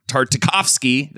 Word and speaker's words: Tartakovsky: Star Wars Clone Wars was a 2003 animated series produced and directed by Jindy Tarkovsky. Tartakovsky. That's, oh man, I said that right.